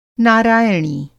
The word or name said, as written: नारायणी